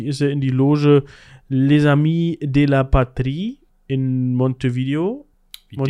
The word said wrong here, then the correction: Montevideo: Montevideo, genau. Mir kam es auch gerade, dass ich es falsch ausgesprochen habe.